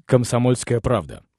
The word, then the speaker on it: Komsomolskaya Pravda: It's Komsomolskaya Pravda.